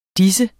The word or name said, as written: dennes